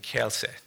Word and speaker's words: Kielce: So why don't you start, Judge, by telling us a little bit about the ghetto in Kielce. Did I say it correctly? – Correct. Perfect.